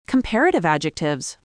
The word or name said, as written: comparative adjectives